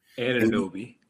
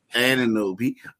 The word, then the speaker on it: Anunoby: But then also you had OG Obanobi. I just butchered his name. – Anunoby. – Anunoby.